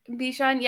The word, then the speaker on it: Bichon: Bichon. Is that the way you pronounce that dog? – Bichon, yeah.